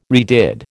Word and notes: redid: American English

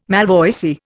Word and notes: malvoisie: Indian

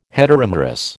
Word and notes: heteromerous: American English

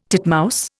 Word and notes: titmouse: Indian

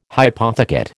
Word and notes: hypothecate: American English

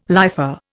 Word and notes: lifer: Indian

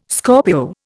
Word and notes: Scorpio: Indian

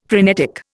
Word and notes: phrenetic: Indian

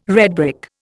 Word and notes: redbrick: Indian

